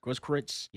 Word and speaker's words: Grosskreutz: How do you say his name? Do you know how to pronounce his name? – Grosskreutz.